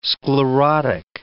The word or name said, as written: sclerotic